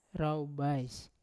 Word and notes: raubais: Yawa

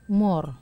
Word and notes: mor: Wamesa

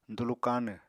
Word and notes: dulu káanw: apatani

